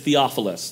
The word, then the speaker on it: Theophilus: That name that you see there is Theophilus. That's how you say that.